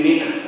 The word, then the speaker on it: مئة: يقينا مخطئ، لا تنطق الألف مطلقا في مئة، يبقى ده من الأخطاء التي ينبغي أن نتوقف عندها، يبقى هي يجوز أن تكتب لكن لا ينبغي أبدا أن تنطق.